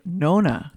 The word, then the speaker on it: Nonna: I would say Nonna. – Nonna. Okay. Well, I can work on my pronunciation.